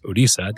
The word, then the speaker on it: Odisha: So my grandmother, my family comes from India, from eastern India, in a state called Orissa, now pronounced Odisha. They keep changing the pronunciation.